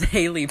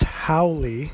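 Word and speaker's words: haole: So this is actually not called Haley Punch. My dad just corrected me. How is this pronounced, and what the hell does it mean? – It's pronounced Howley, and most Hawaiians, of course, know that.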